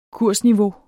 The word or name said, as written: kursniveau